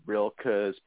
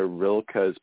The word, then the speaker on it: Rilke: reminds me, because I was first turned on to Rilke's poetry by a friend of mine who's a painter, and still to this day, it's like, you know, he's a fellow artist.